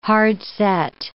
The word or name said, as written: hard-set